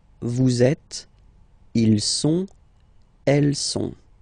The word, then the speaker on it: sont: Okay, ils sont, final T not pronounced, ils sont, elles sont.